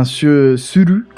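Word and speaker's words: Suru (する): Mais mais ouais, l'idée derrière ce nom en fait, Suru, enfin Suru comme c'est censé être prononcé.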